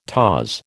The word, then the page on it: taws: American English